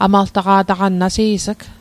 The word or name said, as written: amaltaqa'taqan